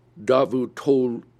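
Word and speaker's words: Davutoğlu: It is Davutoglu.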